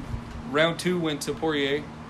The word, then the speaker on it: Poirier: Poirier, yeah.